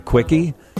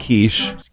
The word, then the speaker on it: quiche: I was at a restaurant yesterday, and the guy next to me asked the waitress for a quickie. She slapped him in the face, and then the other customer leans over to the guy and said, it's pronounced quiche. Quiche, you big dummy.